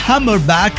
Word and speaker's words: Amorbach: Amorbach en realidad sería pronunciado Hamburbach, sí.